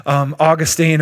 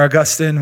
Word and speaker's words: Augustine: Now, I like what one of the early church fathers, Augustine or Augustine, whichever way you like to pronounce it, said.